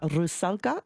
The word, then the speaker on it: Rusalka: He is here in Brisbane to conduct the opera Rusalka.